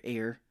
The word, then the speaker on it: Eyre: It's spelled E-Y. E-Y-R-E. It's Eyre, Air.